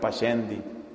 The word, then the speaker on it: Pascendi: Se escribe P-A-S-C-E-N-D-I. Pascendi. Pascendi, la encíclica de San Pío X.